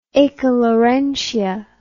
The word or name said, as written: accalarentia